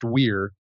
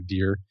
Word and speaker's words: vir: And the word vir, it's pronounced weir. But you can say veer.